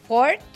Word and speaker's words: Fjord: Está Ford, que bueno, según yo así se pronuncia, es FJORD con Christian Mungi.